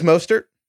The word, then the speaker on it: Mostert: So, first of all, I always get the name wrong. It is Mostert. – Mostert? – Mostert? – No, Mostert? Heifetz always says Mostert. It's Mostert.